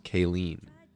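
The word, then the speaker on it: cailín: Colleen sounds an awful lot like the Gaelic word for girl, which I believe is pronounced Kayleen, C-A-I-L-I-N.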